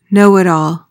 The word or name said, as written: know-it-all